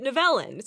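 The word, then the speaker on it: Nivellen: Like the people making the show pronounce this name multiple ways because in the show they say Nivelin, but the actor who plays Nivelin, I was watching some behind-the-scenes stuff, and he pronounced it Nevelin.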